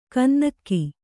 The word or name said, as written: ಗುನ್ನಿಗ